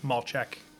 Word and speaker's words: Malchek: Well, Josh Malchek. You'd probably first ask if you're just reading my last name how to pronounce that. – Mm. – Malchek. A lot of consonants in there. It's Polish.